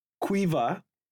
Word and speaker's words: Caoimh: S-I-O-B-H-A-N. Siobhan. Quiver. C-A-O-I-M-H. What is going on here, lads?